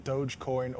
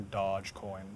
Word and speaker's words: Dogecoin: Uh, it's it's debatable, Dogecoin or or Dogecoin. It, it's it's an internet joke.